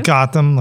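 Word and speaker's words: Gautam: Gotham, like Gotham City, okay. Like Batman and Robin Gotham.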